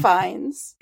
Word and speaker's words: Fiennes: It's Ralph Fiennes. It's always been Ralph Fiennes.